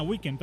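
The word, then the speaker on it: Weeknd: No, se pronuncia como fin de semana, Weekend, pero en realidad no se escribe Weekend.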